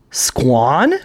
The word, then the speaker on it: Squan: Her name is Squan. – Quan. – It's spelled Guan? – S-Q-U-A-N.